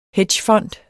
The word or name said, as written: hedgefond